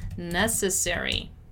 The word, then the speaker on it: necessary: Let's take the chance here to work on the pronunciation of the word necessary. Necessary, necessary. Notice how the stressed syllable, the strongest syllable is ne.